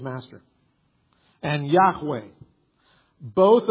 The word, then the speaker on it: Yahweh: When you pronounce those consonants, it's Yahweh.